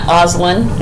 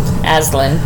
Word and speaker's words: Aislyn: Oslin. – A-I-S-L-Y-N. – Aislinn.